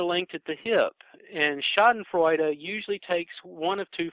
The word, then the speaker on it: Schadenfreude: Was my pronunciation okay? – Yeah. And I mean, I'm certainly not a guru on pronunciation, but yeah, Schadenfreude is a corollary of envy.